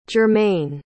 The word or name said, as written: germaine